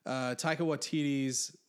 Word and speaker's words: Taika Waititi's: So one, Taika Waititi's, I'm sure, am I saying that right? It doesn't matter. – Yeah, you are.